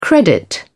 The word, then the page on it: credit: British English